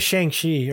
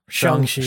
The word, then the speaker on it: Shang-Chi: Same with Shang-Chi or Shang-Chi. However you want to pronounce this version. Both are correct.